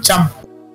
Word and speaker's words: Champú: Shampoo. – Shampoo con acento en la U.